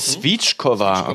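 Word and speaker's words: svíčková: Svitschkova wird das ausgesprochen. – Svitschkova, okay.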